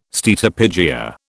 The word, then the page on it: steatopygia: British English